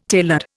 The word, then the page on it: tailored: Indian